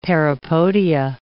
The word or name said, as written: parapodia